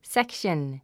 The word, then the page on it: section: sékʃən; セクション